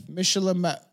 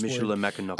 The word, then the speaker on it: Michilimackinac: Yeah, seven a maiden voyage from Niagara to how do we pronounce this word again? Michilimackinac. Niagara to Michilimackinac. It's a very long native word, indigenous Michilimackinac.